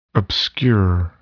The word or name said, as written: obscure